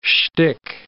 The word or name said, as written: shtick